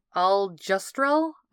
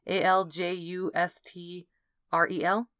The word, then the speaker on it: Aljustrel: Aljustrel? Aljustrel? I'm not sure how to pronounce that. A L J U S T R E L? – Sure. – Sure, that sounds good.